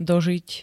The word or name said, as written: dožiť